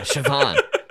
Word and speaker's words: Siobhan: It's pronounced Siobhan. – Siobhan. – Yeah, you'd want to get ahead of that. – Even Siobhan, like, I'm thinking of the word Siobhan right now. Just Siobhan. I can't spell it. I don't even know, like, how I would want to spell it. – Like a Chevy with a Ron? – Like I'm thinking, like, almost Derek thinking, Chauvin.